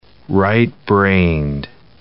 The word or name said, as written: right-brained